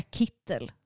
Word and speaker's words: Kittel: Jag har hört både shit Kittel och Kittel, men det är Kittel som mm.